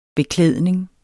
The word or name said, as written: beklædning